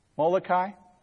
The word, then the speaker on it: Molokai: Joseph Damien went to back in the 19th century, and I think it's pronounced Molokai. Can you help me out? – Molokai. – Oh, I wasn't off too bad.